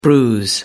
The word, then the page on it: bruise: American English